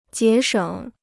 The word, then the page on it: 节省: jié shěng